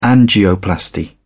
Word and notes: angioplasty: British English

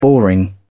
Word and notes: boring: British English

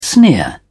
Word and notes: sneer: British English

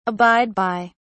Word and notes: abide by: British English

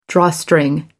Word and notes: drawstring: American English

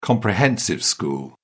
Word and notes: comprehensive school: British English

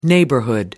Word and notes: neighborhood: British English

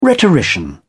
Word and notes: rhetorician: British English

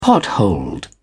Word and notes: potholed: British English

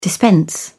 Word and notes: dispense: British English

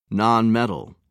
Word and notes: nonmetal: British English

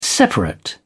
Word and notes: separate: British English

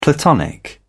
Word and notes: platonic: British English

